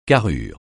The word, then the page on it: carrure: francais